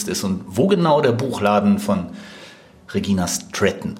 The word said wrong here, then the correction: Stratton: Stratton Entschuldigung, ist. – Sehr deutsch ausgesprochen, ne? Stratton.